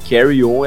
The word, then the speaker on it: Carry On: E Carrion a gente tava falando errado. Carry On é o nome da música mesmo. Essa palavra que é carrion, ou alguma coisa nesse sentido. E significa carniça.